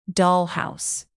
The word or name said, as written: dollhouse